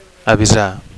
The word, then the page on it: avisar: Catalan